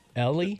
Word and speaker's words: Helly: Yeah, it's pronounced Ellie, just kind of like Ellie? – Spelled differently. Yeah. – Like a silent Ellie. H? – Okay. – Silent H, that's it.